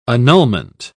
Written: annulment

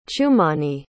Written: chumani

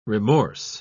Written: remorse

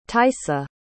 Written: taissa

